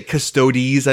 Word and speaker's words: Custodes: You're supposed to say Custodes, that's what I've been told. Like, you have to pronounce every vowel.